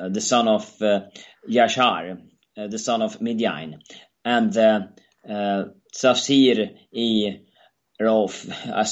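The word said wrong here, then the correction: Tafsir-i Raufi: Tafsir I Raufi. In those languages, they pronounce practically every vowel. Okay, it's very rare that they combine vowels like we do in English. You know, like O and O I into a single syllable. So it's Tafsir I Raufi.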